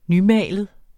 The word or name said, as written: nymalet